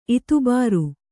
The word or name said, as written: ಇತುಬಾರು